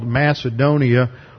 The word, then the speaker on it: Macedonia: And at Troas, the Holy Spirit gave him a vision of a man over here in northern Greece, this area called Macedonia, or as they pronounce it, Macedonia.